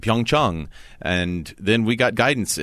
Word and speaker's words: Pyeongchang: In fact, let's talk about that for just a second, because a Korean friend of mine said it's Pyeongchang. And yet we're going to be hearing a slightly different pronunciation with everyone who's a part of NBC.